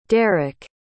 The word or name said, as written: dereck